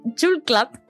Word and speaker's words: Julklapp: Eh, es Jule Club. Es una palabra compuesta de jul, que significa Navidad, y Club, que significa golpecito.